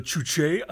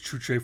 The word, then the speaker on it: Juche: It's called the Chuche idea. It's from it's an experimental feature from Jim Finn. – And that's spelled J-U-C-H-E.